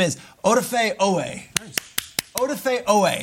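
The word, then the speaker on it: Oweh: My prediction is that not only does he have 2 sacks, but Joe Buck seamlessly pronounces his name correctly, does not call him Owa, does not call him Odafe, and does not mess up his name.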